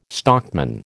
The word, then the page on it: stockman: American English